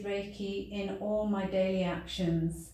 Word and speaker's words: Reiki: Reiki, pronounced Rei like the sun's rays and Ki like the key of the door, is a Japanese word for universal life force energy and describes a system of natural hands-on healing.